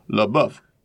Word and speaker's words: LaBeouf: Mac knows that the Beast LaBeouf's name is pronounced LaBeouf.